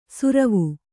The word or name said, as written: ಸುರ್ವು